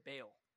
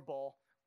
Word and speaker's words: Baal: Everyone say Baal. Baal. Uh, it's like spelled like it's Baal, but instead of two L's, it's two A's. You can say it Baal or ball. you know, whatever. I say Baal.